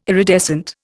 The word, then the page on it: iridescent: Indian